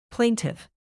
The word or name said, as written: plaintive